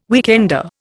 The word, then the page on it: weekender: Indian